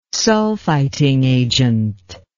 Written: sulfiting agent

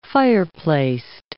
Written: fireplaced

